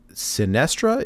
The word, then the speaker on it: Sinestra: So it looks like next up is Sinestra, if I'm pronouncing that right. – That is correct, yes. So Sinestra was the prime consort of Deathwing.